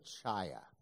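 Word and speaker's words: Chaya (חיה): It's like Chaya, but you kind of sound like you're hawking a loogie right before it.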